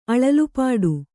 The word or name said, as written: ಅಳಲುಪಾಡು